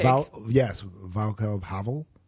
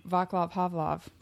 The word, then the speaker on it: Václav Havel: Václav Havel? – Yes. Yes. Who I like to say his name, Václav Havlov. I know that's not how you say it.